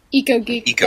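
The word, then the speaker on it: EcoGeek: Echo Tech. Did I say that right? – Eco Geek. – Eco Geek. Thank you.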